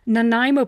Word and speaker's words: Nanaimo: It's Nanaimo bars.